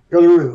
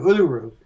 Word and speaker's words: Uluru: How you pronounce Uluru? – It? Uluru. Uluru.